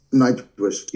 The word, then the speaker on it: Nightwish: Nightwish? Is it Nightwish? I'm not really sure how to pronounce it. – Nightwish. – Yeah, Nightwish. Yeah, we played with them, and that was a great show.